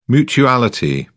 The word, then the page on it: mutuality: British English